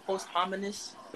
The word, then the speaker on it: posthumous: His posthumous album. – I don't like post. – How do you pronounce it? Posthumous. – Posthumous.